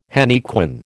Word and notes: henequen: American English

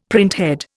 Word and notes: printhead: Indian

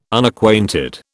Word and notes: Unacquainted: British English

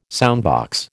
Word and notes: soundbox: American English

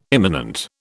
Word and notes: immanent: British English

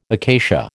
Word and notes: acacia: American English

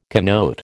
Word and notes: connote: American English

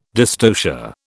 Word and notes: dystocia: British English

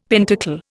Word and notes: pentacle: Indian

